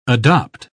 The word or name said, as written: adopt